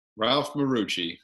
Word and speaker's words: Ralph Marucci: Ralph Marucci.